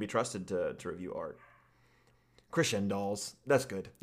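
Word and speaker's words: Crescendolls: I just realized how it's supposed to be pronounced. In my mind, I always pronounce it Crescendals. – Crescendals? – Crescendals. – Yeah. – That sounds so much better. – That makes so much sense. Because crescendo, right. The word crescendo is literally right there.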